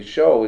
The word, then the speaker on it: shew: And he says, we need to read what it really says there, because if you look at your King James, the show is S-H-E-W. He says, we need to say, study to shew thyself approved. Well, I went and looked it up. S-H-E-W is pronounced show. He was wrong.